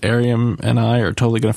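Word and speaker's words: Airiam: And what's Arium? Is that how you say it? – Arium. – Arium.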